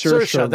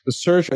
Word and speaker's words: Saoirse: It's Saoirse. – That's right. That's right. – Saoirse. It sounds like inertia. That's how I remember her. – Nobody gave me the trick. Saoirse said she considered changing her name as a child and then just was like, you know what?